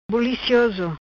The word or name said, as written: Bulicioso